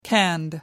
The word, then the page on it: canned: American English